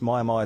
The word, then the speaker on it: Mia Mia: Myer Myer is spelled M-I-A-M-I-A.